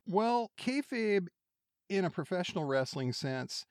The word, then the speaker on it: kayfabe: So Kayfabe, did I say that correctly? – You did.